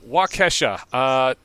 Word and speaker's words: Waukesha: I know K-E-S-H-A is pronounced Kesha, so I'm just going to assume it's Waukesha. – Excellent. So, Waukesha,